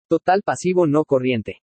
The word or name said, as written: TOTAL PASIVO NO CORRIENTE